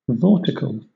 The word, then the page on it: vortical: British English